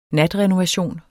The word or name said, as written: natrenovation